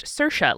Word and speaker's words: Saoirse: I heard a Saoirse Ronan, by the way, say that her name is pronounced Saoirse, like inertia.